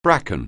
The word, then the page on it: bracken: anglais